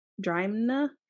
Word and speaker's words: Drimnagh: D-R-I-M-N-A-G-H. Drimna. – Say it again slower. – Drimna. – Yeah, it's like the A-U-G-H is like a like the sound plow. Ow.